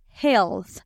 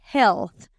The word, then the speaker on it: health: Health, health. Se escribe H-E-A-L-T-H. E Lo que pasa es que la A es silenciosa aquí. Entonces decimos health.